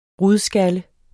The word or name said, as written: RUDSKALLE